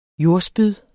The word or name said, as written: jordspyd